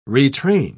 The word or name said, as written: retrain